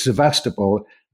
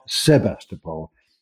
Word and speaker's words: Sevastopol: and then he, he yes, it's Sevastopol, not Sebastopol. And he blames early translations of War and Peace for this.